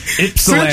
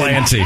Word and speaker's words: Ypsilanti: Ysplanty. – Ysplanty. – Yips. We'll it looks like Ysplanty. – We'll take Ypsilanti or Ypsilanti. I think the locals say Ypsilanti. Either way, it's not Ysplanty.